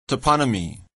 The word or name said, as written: toponymy